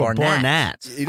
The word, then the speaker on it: Barnatt: I've always said Barnett, but it's spelled with an A. Is it Barnett? – It's Barnatt. – Oh, Barnatt.